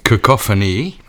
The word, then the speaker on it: Cacophony: Het nummer, ja, heb ik moeten opzoeken met hoe dat het uiteindelijk uitgesproken werd. Cacophony.